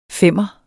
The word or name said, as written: femmer